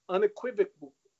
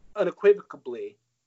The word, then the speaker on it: unequivocally: And here's the reason that Rob Manfred backpedaled like crazy after on Friday saying unequivocally. – Did I say that right? No, but close. – Unequivocally.